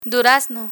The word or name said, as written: durazno